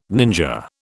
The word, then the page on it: ninja: British English